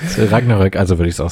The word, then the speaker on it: Ragnarök: Ja, ich glaube aber tatsächlich, dass es Ragnarök ausgesprochen wird. Also in allen Medien, die sich auch damit beschäftigen, und aber auch, ich habe Vikings geguckt, auch im Englischen sagen sie es so, sie sagen es im Deutschen so.